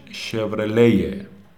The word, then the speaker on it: Chevrolet: البته شورلت تلفظ ایرانیشه یا حتی شورلت هم گفته میشه ولی تلفظ آمریکاییش شورلیه تلفظ فرانسویش میشه احتمالا اون وسط های ری رو با یه قی تقریبا مخلوط کنید و بگیدی من خودم دقیق نمیتونم بگم یه تو مایه های مثلا شوغلی یا چیزی